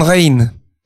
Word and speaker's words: Rijn: Il s'appelait Rembrandt van Rijn. – Ah. – En van, van, V-A-N, et R-I-J-N, qui se prononce R-A-I-N.